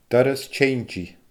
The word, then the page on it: doras-teinntidh: Scottish Gaelic